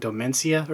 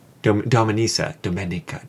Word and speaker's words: Domenica: Isn't it like Domencia or something? – Dominions. – How is it spelled? – Again, continuing our theme of not knowing how to pronounce anything. – Domenica. – Domenica. – You're right. That's an Issa. That's definitely not an Ica. Domenica. – Was it I-A? – D-O-M-E-N-I-C-A. – Domenica. – No, it's Domenica, then.